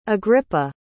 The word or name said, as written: agrippa